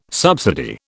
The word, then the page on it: subsidy: British English